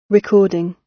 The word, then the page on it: recording: British English